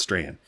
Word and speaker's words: Strahan: Let me spell it for you. Strachan. Yeah, it's Strahan. Owen Strahan.